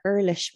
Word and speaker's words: Urlichs: So we think it's Jessica Urlichs. It's actually Jessica Urlich, but everyone says it wrong, she said. But I'm going to say it right out of respect for this.